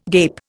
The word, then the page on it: gape: Indian